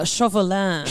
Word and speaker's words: Chauvelin: Chauvelin. – Just think chauvinistic. – Yeah. – Chauvelin. – Or think chocolat. – Or chocolat. – But not say that.